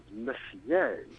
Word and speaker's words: Messier: I make one little correction, that the astronomer's name is Charles Messier. – Messier, okay. – Messier. – So it was my pronunciation that was messier. But it's spelt the same way, except he's French. Yeah, so being French. And if that were my name, I would be using the French pronunciation anyway.